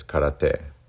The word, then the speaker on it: karate: And then we'll talk about karate, or what we say, you know, in Japanese, the pronunciation is karate.